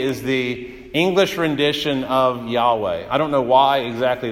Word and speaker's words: Yahweh: And his name is Yahweh. That's the way we pronounce it. Some would pronounce it Jehovah, but a more proper rendering is Yahweh.